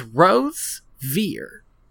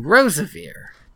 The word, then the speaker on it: Rosevere: Pronouncing his name wrong. – Is it not? – It's Rosevere. And we've been saying Rosevere.